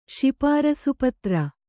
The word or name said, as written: ಶಿಪಾರಸುಪತ್ರ